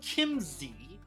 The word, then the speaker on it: Chiemsee: so the map itself, I believe, uh, I'm, I'm going to say it right because I got schooled and chat about that from Gumpy. It's called Chiemsee.